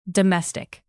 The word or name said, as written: domestic